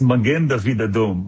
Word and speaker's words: Magen David Adom: So you got it right on the second time. It's Magen David Adom.